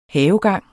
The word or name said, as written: havegang